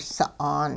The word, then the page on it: sa'aan: hupa